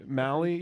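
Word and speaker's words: Maile: The entire season, the fans have been saying, bring up Luke Maley. Or Maley? – Malley. – Yeah, bring up Luke Malley, whatever.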